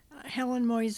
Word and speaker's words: Helen Moise: Helen Moise. – Can you spell it? – H-E-L-E-N-M-O-I-S-E.